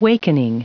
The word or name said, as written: wakening